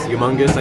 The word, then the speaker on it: humongous: I saw it in the, I don't pronounce the H. I didn't know that until someone mentioned that to me. I think it's because my grandma's French, but human race, humongous. I never say the H.